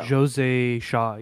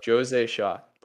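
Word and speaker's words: José Sá: Shaw, it is Jose Shaw. No. – Jose Shaw, yeah. Jose Shaw. – Yeah, Jose Shaw. Portuguese, weird language.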